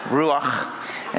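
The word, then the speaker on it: Ruach (רוח): And so in the Old Testament, which is the Hebrew language, the word is ruach. You like how I did that? Gave a little at the end, like you got something, like you got popcorn kernels in your throat. Ruach.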